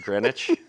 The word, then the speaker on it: Greenwich: Greenwich, yeah.